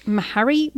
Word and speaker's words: Meharry: So Brandon earned his MD at Meharry Medical College.